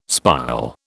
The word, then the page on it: spile: British English